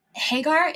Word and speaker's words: Hagar: And you talk quite a bit about Hagar.